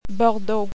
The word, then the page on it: Bordeaux: French